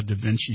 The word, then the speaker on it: Da Vinci: But what exactly is a Da Vinci surgical system? – Well, it's am I even saying it right? – Yeah, you are.